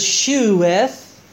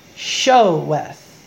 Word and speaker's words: sheweth: The King James uses sheweth, but it's pronounced showeth.